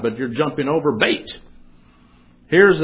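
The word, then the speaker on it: bait (ב): Because that Hebrew letter that you see there, pronounced bait, is actually in the original.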